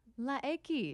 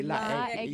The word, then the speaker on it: La X: And also, and I'm not going to try and say it because I'll butcher it, La X. – Okay. – Yeah, tell you, La X. That's how you got to say La X. Yeah, the X, La X.